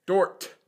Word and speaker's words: Dordt: Okay, people who are listening, it's not Dork. It's spelled D-O-R-D-T. Dort.